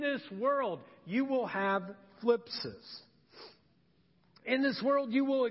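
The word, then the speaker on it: phlipsis (θλῖψις): You don't pronounce the TH, so let's say that out loud, phlipsis.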